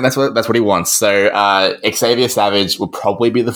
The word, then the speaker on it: Xavier: The fullback is probably going to be Xavier Savage, and I say Xavier because that's how he asked to have it pronounced, which I know is shocking.